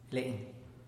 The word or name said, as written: le'n